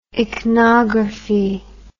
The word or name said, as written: ichnography